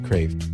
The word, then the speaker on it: Kreeft: And that's by Dr. Peter Kraft, K-R-E-E-F-T. I know it looks like Kraft, but he pronounces it Kraft.